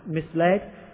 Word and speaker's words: misled: For a long time he mentally pronounced misled as if it rhymed with sizzled.